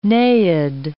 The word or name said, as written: naiad